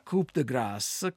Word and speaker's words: Coup de Grâce: Si pronuncia Coup de Grâce, come colpo di grazia,